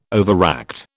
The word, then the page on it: overact: British English